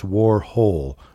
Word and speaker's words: Warhol: Where he not only teaches people how, how to pronounce Andy Warhol, which he says it's Warhol, not Hall. It's whole, as in Warhol. He goes to this whole lot. It's Warhol, as in hole.